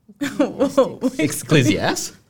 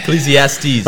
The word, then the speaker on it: Ecclesiastes: Ecclesiastes. We talking about the Bible here. – Yeah. Okay. – Ecclesiastes.